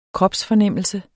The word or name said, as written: kropsfornemmelse